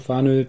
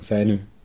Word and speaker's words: Fanø: Also die Deutschen sagen Fanø, die Dänen sagen Fänø.